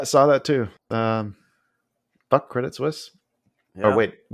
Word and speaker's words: Credit Suisse: Actually, I got Coil Spotting was kind enough to tell us how to properly pronounce that. – Okay. Suisse. – Sorry, Coil. I'm looking for your message. – Stussy. – Credit Suisse. That is the proper pronunciation. Credit Suisse.